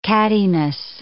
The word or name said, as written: cattiness